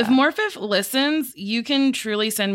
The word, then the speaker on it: Morfydd: one of the stars, oh boy, Moy Firth Clark reports, One Moifer. Oh, I'm really sorry if you hear this. – Morfydd, but it's spelled M-O-R-F-Y-D-D.